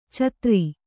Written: ಛಾದರು

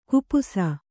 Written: ಖಪ್ಪಸು

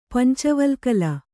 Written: ಪಂಚವಲ್ಕಲ

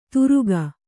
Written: ತುರುಕ

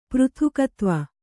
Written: ಪೃಥಕ್ತ್ವ

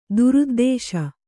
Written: ದುರುದ್ದೇಶ

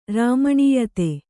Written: ರಮಣೀಯತೆ

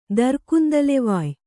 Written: ದರ್ಕುಂದಲೆವಾಯ್

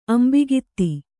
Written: ಅಂಬಿಗಿತ್ತಿ

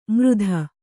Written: ಮೃಧ

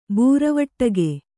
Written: ಬೂರವಟ್ಟಗೆ

